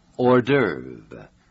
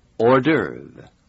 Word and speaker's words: Hors d'oeuvre: Hors d'oeuvre. H-O-R-S. And the last part is spelled D apostrophe O-E-U-V-R-E. Hors d'oeuvre. – Hors d'oeuvre 在法文里的意思是主菜以外的食品。